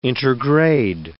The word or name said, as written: intergrade